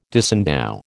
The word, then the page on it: disendow: American English